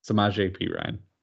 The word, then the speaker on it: Samaj Perine: Samaj Perine.